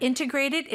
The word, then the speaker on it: integrated: NT가 모음 사이에 껴있으면 mm-hmm. 희한하게 T가 되게 약화되는 mm-hmm. 현상이 있는 integrated. – 것 같아요. Right? – Mm-hmm. Okay. But integrated is just as good.